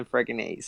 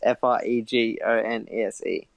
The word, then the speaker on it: Fregonese: You can just kind of sound it out, just like Sam Fregonese. So just Fregonese.